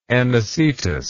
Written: Anicetus

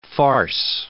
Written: farce

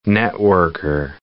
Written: networker

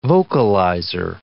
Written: vocalizer

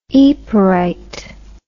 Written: yperite

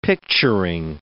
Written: picturing

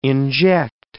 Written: inject

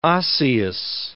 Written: osseous

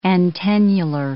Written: antennular

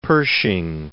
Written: Pershing

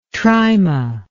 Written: tryma

